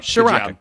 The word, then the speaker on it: Ciarrocca: Ciarrocca. – Right now, look at me. Yep, Ciarrocca.